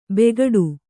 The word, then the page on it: ಬೆಗ್ಗಾಡು: beggāḍu